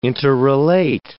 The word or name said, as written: interrelate